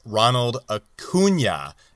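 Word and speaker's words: Ronald Acuña: It's pronounced Ronald Acuna, not Acuna.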